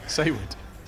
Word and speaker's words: Saward: Joe Saywood, Saward, just Sawawad. How do you say his name? – Saywood. – Saywood.